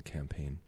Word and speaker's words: campaign: The G is silent in campaign.